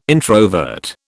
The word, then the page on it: introvert: British English